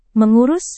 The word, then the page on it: mengurus: Indonesian